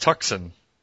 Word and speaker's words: Tucson: Which is actually pronounced Tucson.